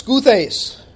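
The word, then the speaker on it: skuthes (Σκύθης): But that Scythian, Scythian, literally the Greek word is skuthes. Skuthes. So pronounce it however you want. It's skuthes.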